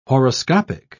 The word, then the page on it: horoscopic: British English